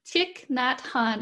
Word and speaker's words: Thich Nhat Hanh: Thich Nhat Joy? – Thich Nhat Hanh.